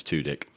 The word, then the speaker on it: Tudyk: I had to watch a video before today to work out how to actually pronounce his last name. – Who's that? – Wash from Firefly. – Oh, right. – Yeah. – Yeah. His last name's Tudyk.